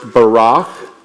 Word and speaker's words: barach: The first one in Hebrew, this is Hebrew word, and it's pronounced barach, and it means blessing.